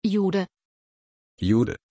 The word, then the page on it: jude: German